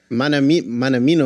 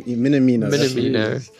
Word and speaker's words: Minamino: Manamino or something. – Minamino.